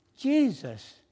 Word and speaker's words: Jesus: and then it was pronounced Jesus with the J sound, hard J.